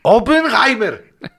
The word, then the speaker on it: Oppenheimer: ¡Oppenheimer! ¿Lo pronuncié bien? Eh, sí, perfecto.